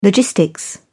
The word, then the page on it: logistics: British English